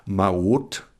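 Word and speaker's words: Maot (מעות): Maot. Maot heißt Münzen. Und die Juden, die in Mitteleuropa gelebt haben, nicht im Orient, die haben dieses Hebräisch anders ausgesprochen. Und so ist als Maot Maues oder Mäuse geworden, wenn man es im Plural nimmt. Oder es ist Moos geworden, weil man im Hebräischen das T am Ende als S ausgesprochen hat.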